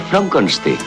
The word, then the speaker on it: Frankenstein: Se pronuncia Frankenstein.